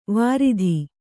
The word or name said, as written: ವರಾತು